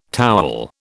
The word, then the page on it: towel: British English